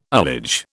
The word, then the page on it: ullage: British English